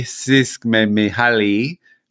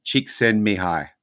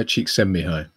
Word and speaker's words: Csikszentmihalyi: And why I guess this caught my eye, especially because we've spoken about this man, gentleman, before, and we've called him Mikhaili Csikszentmihalyi, which is not really a great pronunciation, but he passed away last week. But this is how the phonetic guide is Mihai Csikszentmihalyi. – Mihai Csikszentmihalyi. Yes.